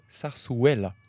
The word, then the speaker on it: zarzuela: Alors, je tiens à dire d'abord que nous avons toutes mal prononcé parce que les deux Z se prononcent S et le U se prononce ouais. Donc on dirait plutôt zarzuela.